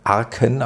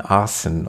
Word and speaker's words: Arcen: Genau, er wird geschrieben A-R-C-E-N. Man könnte auch meinen Arken, aber ich glaube, er wird Arcen ausgesprochen. Ist in der Nähe von Venlo,